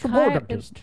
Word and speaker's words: chiropodist: Oh, she doesn't know how to pronounce chiropodist. – It looks like chiropractor, but it's chiropodist.